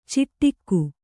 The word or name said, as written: ಚಿಟ್ಟಿಕ್ಕು